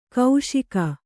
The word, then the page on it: ಕೈಶಿಕ: kaiśika